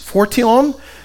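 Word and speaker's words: phortion: It's spelled almost like portion with an H, fortion, which simply means this: a responsibility, or to assume responsibility of life.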